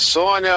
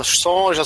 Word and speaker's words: Sonja: Ah, é, diz a pronúncia correta que é Sônia, ah, se escreve Sonja, o gibi aqui, a molecada sempre falou Sonja,